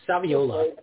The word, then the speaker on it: Saviola: And then I'm not sure how these birthdays work out, but we're going to start off with Camellia Savioli, hard to pronounce that. – I think it's Saviola. Yeah, Camille Saviola. – Yeah, I think you're right.